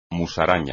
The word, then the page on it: musaraña: espagnol